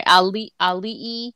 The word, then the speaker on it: Ali'i: Ali'i is how you say Ali'i. It. I'm sorry. Ali'i.